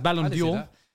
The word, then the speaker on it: Ballon d'Or: He says Ballon d'Or. Like the brand Dior.